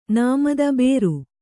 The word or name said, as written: ನೇಮಿತ